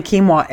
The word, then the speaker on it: quinoa: And that's spelled just so those people who are like, what is that? People are talking about it. It's Q-U-I-N-O-A. – Yep. – Quinoa. – Quinoa. – Yeah, quinoa. So why would we recommend something like quinoa?